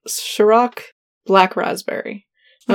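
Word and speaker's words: Cîroc: I don't remember when, but I think it's pronounced Ciroc. It's like C-I-R-O-C, but it's got like a little hat on top of the I.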